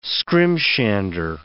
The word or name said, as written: scrimshander